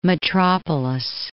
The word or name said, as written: Mitropoulos